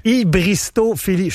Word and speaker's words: hybristophilie: Hybristophilie ou folie? Ouais. – Non, philie. – OK. – Hybristophilie.